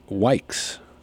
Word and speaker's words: Wykes: We had the 100-year-old realtor, Mary Wykes, rhymes with likes.